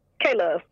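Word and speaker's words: K-Luv: So it's not the love that's spelled L-O-V-E. It is special because it is L-U-V. – L-U-V. – So it is the love you vision. So, yes, I'm K-Love.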